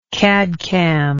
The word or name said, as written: cadcam